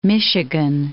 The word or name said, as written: Michigan